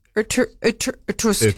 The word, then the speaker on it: Irkutsk: Irkutsk.